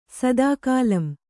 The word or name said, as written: ಸದಾಕಾಲಂ